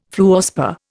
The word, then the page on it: fluorspar: Indian